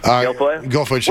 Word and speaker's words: Guilfoyle: Guilfoyle.